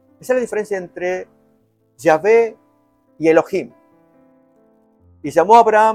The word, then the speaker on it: Yahvé: Ustedes recuerden que va a variar la forma de pronunciarlo. La forma más correcta sería Yahvé. Jehová no, aunque prevaleció más en las Biblias.